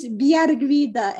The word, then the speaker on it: Bigarvida: My last name is pronounced Bigarvida, and I am one of like 15 Bigarvidas in the whole world.